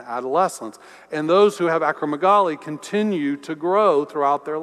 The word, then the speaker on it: acromegaly: Well, Malcolm Gladwell suggests that possibly the reason he was so big is that he had a malady called acromegaly. Sorry, I didn't pronounce that quite right. Acromegaly.